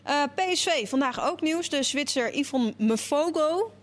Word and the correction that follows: Mvogo: Mvogo, zei hij. Mvogo.